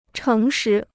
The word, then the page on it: 诚实: chéng shí